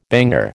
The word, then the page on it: banger: American English